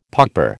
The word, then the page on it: pauper: American English